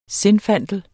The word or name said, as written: zinfandel